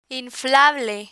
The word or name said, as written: inflable